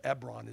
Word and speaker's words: Hebron: He gave him Hebron, or Ebron is the way we would pronounce it. Some people read Hebron. It's H-E-B, one of the famous places in the Bible.